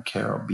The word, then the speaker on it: Carroll: My book at me, so you could contact me via my Facebook, which is my name, Brian Carroll,